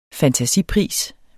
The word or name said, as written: fantasipris